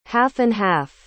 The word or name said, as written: half-and-half